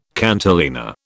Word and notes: cantilena: British English

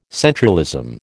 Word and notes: centralism: American English

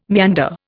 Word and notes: meander: Indian

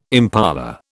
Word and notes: impala: British English

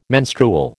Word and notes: menstrual: American English

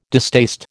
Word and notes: distaste: American English

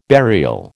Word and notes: burial: American English

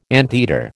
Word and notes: anteater: American English

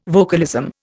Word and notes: vocalism: Indian

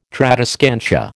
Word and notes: tradescantia: American English

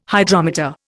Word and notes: Hydrometer: Indian

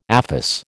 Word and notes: aphis: American English

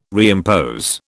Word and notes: reimpose: British English

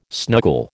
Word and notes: snuggle: American English

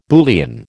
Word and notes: Boolean: American English

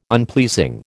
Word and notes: unpleasing: American English

